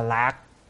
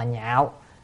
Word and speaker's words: nhạo: Tín nhạo ở đây tín là niềm tin, chữ này có khi đọc là lạc, lạc là vui, nhưng mà phải đọc là nhạo.